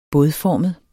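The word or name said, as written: bådformet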